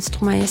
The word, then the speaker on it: Stromae: Stromae.